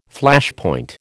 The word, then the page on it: Flashpoint: American English